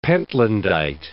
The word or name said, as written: pentlandite